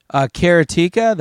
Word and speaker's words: Karateka: Karateka, that karate game.